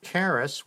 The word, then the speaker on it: charis: The Christian doctrine of grace, from the Greek charis, which rhymes with Harris, received from God through the Holy Spirit, is one of the foundational beliefs of the Christian faith.